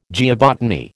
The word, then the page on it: geobotany: American English